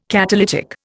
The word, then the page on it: catalytic: Indian